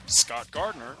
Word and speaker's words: Scott Gardner: You can find me, Scott Gardner, both on Twitter and Facebook. My name is spelled S-C-O-T-T, GARDNER.